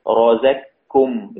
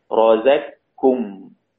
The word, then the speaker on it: رزقكم: إذن يقرأ الإمام السوسي عن أبي عمرو بإضغام القاف والكاف هكذا، رازاكم إضغام كامل، رازاكم، تدخل القاف في الكاف فلا يوجد أثر لها، ننطق ننطق كاف مشددة، رازاكم.